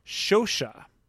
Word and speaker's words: Scioscia: So as we flip to the back of the card, we see Mike Socha spelled S-C-I-O-S-C-I-A. And having taken one semester of Italian and being able to use Google, I would say that this name should be pronounced Socha. – Who knows when it got Americanized to Socha, taking out the first sh sound.